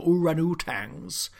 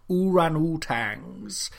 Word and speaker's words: orangutans: We're cheeky chimps, we're gregarious gibbons, and frankly awful orangutans, or orangutans, to use the correct pronunciation, and I will only use the correct pronunciation, orangutans.